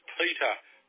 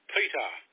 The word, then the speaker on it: Peter: My name is Peter, Papa Echo Tango Echo Romeo. Peter, and it's a pleasure to meet you also.